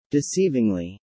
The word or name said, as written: Deceivingly